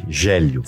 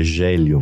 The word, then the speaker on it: Gélio: É Rogério? Não, é Gélio mesmo, né? Tem muito Célio, mas Gélio eu sou o único.